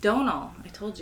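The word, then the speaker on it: Domhnall: Donald Gleason is on a lot. – We should say it properly. – I don't know how to. – Donal, I told you.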